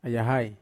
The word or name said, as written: ayahai